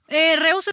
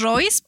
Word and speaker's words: Reuss: O sea, se supone que es Royce, pero luego cuando digo mi nombre bien, o sea, bien pronunciado, se batalla para que me encuentren Reus en redes sociales, okay. Porque se escribe diferente, entonces exacto. Reus está bien. – Se escribe así para que la busquen en redes sociales, Betsy Reus. R-E-U-S-S.